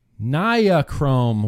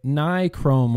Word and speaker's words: Nichrome: Okay, so this person was giving me a hard time because on Tuesday, Bro Tuesday, we talked a lot about Niachrome wire, or as I call it, Niachrome wire, as normal people call it, Nichrome wire.